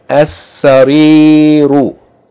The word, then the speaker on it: as-sariru: It should be read as as-sariru, as-sariru, meaning the bed. Seen is a sun letter, so it has to be doubled.